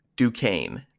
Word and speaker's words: Duquesne: Duquesne is pronounced Duquesne, not Duquesne.